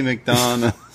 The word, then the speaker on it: McDonagh: Every time I hear them say JD McDonagh, I say it like the Beatles song Lady Madonna, but it's JD McDonagh.